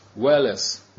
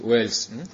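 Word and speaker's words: Wales: Но если игнорировать английскую фонетику и просто прочитать, как это слово пишется, то пишется это Уэллес. Уэллес читается по-английски Уэльс.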